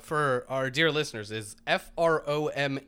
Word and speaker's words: Fromm: I'm gonna break with the pack and say it's a, a long O and say from. Uh, according to our pronunciation guide, it's uh, F R. It's pronounced more like F R O O M.